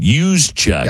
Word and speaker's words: Juszczyk: I'm probably going to say this wrong, but the NFL has fined San Francisco 49ers fullback Kyle Juszczyk, I believe is how you say it, Juszczyk. – Yes, that's correct.